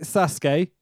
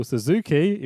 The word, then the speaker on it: Sasuke: Great Sasuke, who they call Suzuki, even though that's not how you pronounce his name, Gran Hamada, and what was meant to be Gran Naniwa, but is replaced by Masato Yokosushi.